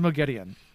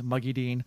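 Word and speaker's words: Moghedien: But that woman over there is Mogedion, and or as they say in the audiobooks, Muggy Dean. They pronounce it wrong, which I think is hilarious.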